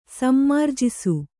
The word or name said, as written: ಸಮ್ಮಾರ್ಜಿಸು